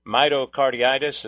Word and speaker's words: myocarditis: Conducted an analysis of regarding the elevated risk of heart attacks due to myocarditis. Is that how you pronounce that? – Myocarditis, yep.